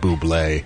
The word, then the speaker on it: Bublé: I believe his name is Bubble, but he chooses to pronounce it Bublé. Yeah. – He does insist on an accent on that final E.